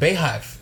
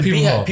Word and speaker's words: Beyhive: I'm happy that you pronounced it Beyhive as opposed to Beehive. People are saying Beehive. I'm happy you said Beyhive.